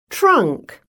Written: trunk

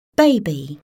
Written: baby

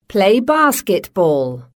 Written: play basketball

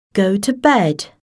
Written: go to bed

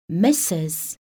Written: Mrs

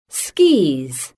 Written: skis

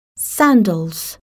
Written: sandals

